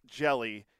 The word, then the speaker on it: Hjelle: It's, as in jellyfish. – Yeah, it's spelled H-J-E-L-L-E, but it's pronounced Jelly.